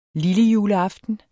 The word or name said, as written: lille